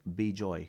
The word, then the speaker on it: Vijay: Yeah, but like in Bengali and other languages, if my name, Vijay, is pronounced in Kolkata as Bijoy.